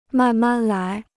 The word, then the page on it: 慢慢来: màn màn lái